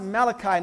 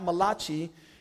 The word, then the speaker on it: Malachi: And it's pronounced Malachi, not Malachi. He was Hebrew and not Italian, all right?